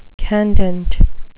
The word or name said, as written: candent